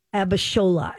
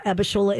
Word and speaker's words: Abishola: Abishola. Abishola is a Nigerian name.